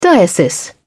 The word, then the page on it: diocese: British English